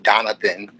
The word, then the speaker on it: Donathon: And Donathon came out again, pronounced the same way you say Jonathan, but it has a D, spelt different than Jonathan, Jonathan as well. But again, pronounced the exact same way, except for with the letter D.